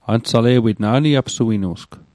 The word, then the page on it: ansale'wit: Mi'gmaq